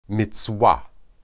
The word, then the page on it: mizwah: Hebrew